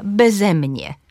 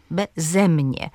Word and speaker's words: beze mnie: Jeżeli przyimek jest dwusylabowy, to na drugą sylabę tego przyimka, beze mnie, beze mnie.